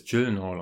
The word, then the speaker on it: Gyllenhaal: Naja, es ist ja ursprünglich ein schwedischer Name, aber er ist ja Amerikaner. Wie spricht man es richtig aus? – Als, naja, er, als er, selber, er selber spricht es Gyllenhaal aus, weil in Amerika niemand Schwedisch spricht.